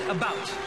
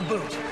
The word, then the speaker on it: about: I speak English and French, not American, and I pronounce it about, not a boot.